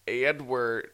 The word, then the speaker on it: Eadweard: Our current catalog includes Slotzer, Helios, early photographs by Edward Muybridge. His name is spelled E-A-D-W-E-A-R-D. Edward. It's like...